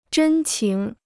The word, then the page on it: 真情: zhēn qíng